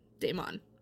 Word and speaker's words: daimon: Yes. and that's spelled D-A-I-M-O-N. Damon.